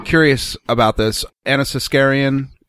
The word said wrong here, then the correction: Anita Sarkeesian: Okay. Sarkeesian? – Yes. Anita Sarkeesian. – What did I say? – Anna Skenesian? – Yeah, that's not it. – Anna talk into the microphone and say it, please. – Oh, Anita Sarkeesian.